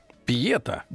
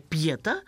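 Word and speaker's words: pietà: Пьета? Да. Э, ну, э, по латыни ударение пьета, а русское слово мы знаем как пьета.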